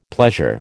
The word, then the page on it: pleasure: American English